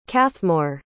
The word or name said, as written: cathmore